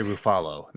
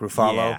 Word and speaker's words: Ruffalo: Or wait, I say Ruffalo. That's my pronunciation. Mark Ruffalo. Yeah. – And way to just start that with the correct pronunciation.